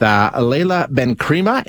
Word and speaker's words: Laila Benkrima: We're going to speak with Leila Benkrema, if I'm saying that right, an agronomy.